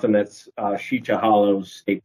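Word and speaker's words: Sica Hollow: So we were in, I think we were out of high school, but we would go to Sheecha. I'm going to use that pronunciation because that's what we use. Sheecha Hollow.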